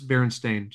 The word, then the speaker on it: Berenstain: But it's Berenstain, just saying. It's spelled Berenstain. The last five letters spell stain, not steam.